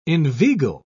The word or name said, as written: inveigle